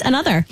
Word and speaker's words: ANOTR: It's spelled A-N-O-T-R on Notter, it looks like, but they're pronounced another.